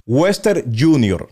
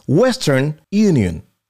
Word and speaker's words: Western Union: Western Junior, no se dice así. Eso está incorrecto, no es Western Junior. Esa compañía no existe, es Western Union, de Unión.